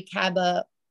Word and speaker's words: caballeros: I feel like a lot of people skip the Grand Fiesta Tour with the three caballeros. Am I saying that right? Yes.